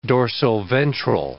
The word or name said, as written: dorsoventral